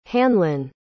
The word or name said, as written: hanlon